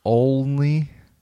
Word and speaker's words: Only: How do you say it? Only? – Jerry Only.